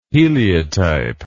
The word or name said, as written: heliotype